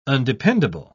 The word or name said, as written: undependable